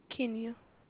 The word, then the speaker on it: Kenya: Kenya. – Oh, Kim, Kenya? – K-E-N-Y-A.